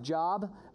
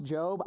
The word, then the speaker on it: Job: It looks like Job, but it's pronounced Job.